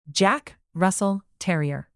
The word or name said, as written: jack russell terrier